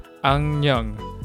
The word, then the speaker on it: 악령: 악령. 악령. 악령. The spelling is 악령, but it's pronounced as 악령.